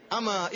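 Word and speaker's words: ama (أما): Jadi, jangan salah baca, jangan dibaca amma, sebagian ikhwan, sebagian tullab membacanya amma inni lam akun fi salatin, salah ya. Bacanya ama inni.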